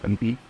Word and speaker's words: Ponsonby: Ich habe es Zimtkalf ausgesprochen, aber wenn du es rotgoogelst, das ist Ponsonby.